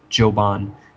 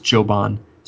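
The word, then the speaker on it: Çoban: And in Turkish, it's actually C-O-B-A-N is the root word. And in Turkish, the C makes a kind of a J sound, J, Joban. And so the root word, it would be pronounced Joban.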